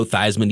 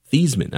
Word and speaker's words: Theismann: His name is actually pronounced Theismann. I don't know if you guys have heard this story, but he was Joe Theismann. He changed his name to Theismann in 1970